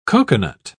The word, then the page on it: cocoanut: British English